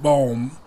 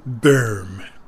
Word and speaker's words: Böhm: And now he gives his name the American pronunciation of Böhm, rather than the German Böhm.